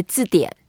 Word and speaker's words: 字典: In Chinese, we say 字典.